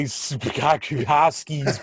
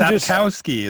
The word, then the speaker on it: Sapkowski: Andrei Sapkowski's book. – But I'm looking at his books right now, and I can't pronounce that last name. – Right. And I'm Sapkowski.